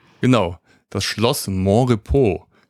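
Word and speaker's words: Monrepos: Ich hatte mich kurz gefragt, wie es ausgesprochen wird, aber ich hatte so die Vermutung, dass es irgendwie französisch ausgesprochen wird, ne? – Ja, wird es auch. – Ja, genau, Mont-Repos. Deswegen Montrepos, nicht Montrepos.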